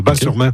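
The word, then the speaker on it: Batz-sur-Mer: Et surtout, la crêperie s'appelle le Dervin à Bas-sur-Mer, qui font les meilleures crêpes de on prononce Bas, le T ne se prononce pas, Bas-sur-Mer.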